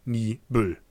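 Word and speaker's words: Niebüll: also heißt es eigentlich auch richtig ausgesprochen dann also eine neue Siedlung, Nie Büll. Der ja schon behandelte Nordseeradweg führt auch in der Nähe von Niebel vorbei.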